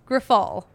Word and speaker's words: Grifol: And of course, we had to talk to our dude out in Chicago, CHGO, White Sox, their own Herb Lawrence about Pedro Grifol. I think that's how you say his. No, that is how you say his name.